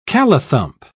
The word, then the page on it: callithump: British English